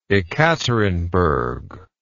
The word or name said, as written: Ekaterinburg